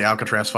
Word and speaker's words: Alcatraz: Honestly, my favorite BOW was, again, I'm just going to go out and say that the Alcatraz fight was my favorite part of the movie. That fucking, did I say that right? – Yeah. – Did I say Alcatraz? – Yeah.